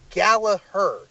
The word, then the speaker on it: Gallagher: I don't think honestly, I mispronounce his name all the time, and it's actually Gallagher.